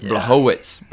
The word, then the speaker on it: Błachowicz: Israel Adesanya was defeated by Błachowicz over the weekend. Or, no, I'm saying that wrong. It's Błachowicz. Błachowicz, the Polish name.